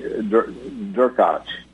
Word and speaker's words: Derkach: His name is Andrei Derkach, that's how you say it.